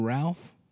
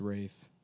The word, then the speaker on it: Ralph: Isn't his name Ralph? – It's pronounced Rafe.